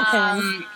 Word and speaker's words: Caine: Kane. – Yeah, it's Michael Caine.